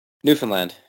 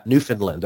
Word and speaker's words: Newfoundland: Newfoundland, yeah. – Newfoundland, okay.